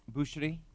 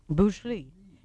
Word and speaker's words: Boucherie: Boucherie. – Boucherie.